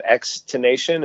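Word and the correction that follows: XXXTentacion: is that? – X-X-X-Tentacion? – That's it, yeah.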